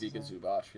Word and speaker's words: Zubac: How do you pronounce Zubac, yeah.